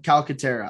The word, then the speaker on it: Calcaterra: Said Calcaterra, but I'm also mispronouncing everyone's name today. Although I got Enyi for some strange reason from Iowa State. Okay. – Somehow. Cool. All right. – So, just me, man. You're doing your best. – All right. So I'm quickly, frantically, Calcaterra. Yep, Calcaterra. I got that wrong.